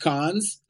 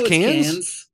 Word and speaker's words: Cannes: It's not pronounced Cannes. – It's Cannes.